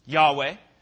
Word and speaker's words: Yahweh: Who knows what it's actually said, how it's pronounced? Yahweh.